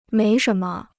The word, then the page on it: 没什么: méi shén me